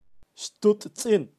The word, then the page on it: xtutzin: Kaqchikel